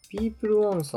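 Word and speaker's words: PEOPLE 1: ピープルワン？ – そう、名前、なんか読み方違った気がするけど、ピープルワンで合ってんのかな？ – 読み方はね、調べてピープルワンだった。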